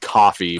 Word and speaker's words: Ko-fi: Apparently it's just pronounced coffee, I say Ko-fi. – Got it two times and I got it wrong. Coffee, Ko-fi.